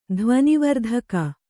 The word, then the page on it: ಧ್ವನಿವರ್ಧಕ: dhvani vardhaka